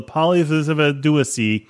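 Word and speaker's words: Phyllopteryx dewysea: So in April of 2016, a research team filmed for the first time a live specimen of the polyvivaduacy. That's actually how you pronounce it. Don't try to correct me.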